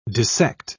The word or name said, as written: dissect